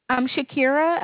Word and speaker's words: Shaquira: Shakira. It's spelled S-H-A-Q-U-I-R-A.